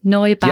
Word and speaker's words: Neubauer: So, for instance, the case of Neubauer, have I pronounced that correctly? Neubauer, yeah. – And Germany. – That's right, yeah.